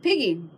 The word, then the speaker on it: pigge: Now, in Saxon times, pigs, which obviously were a big farm animal, were known as pigga, P-I-C-G-A, but eventually changed to P-I-G-G-E, pronounced piggy, which is where we get piggy from.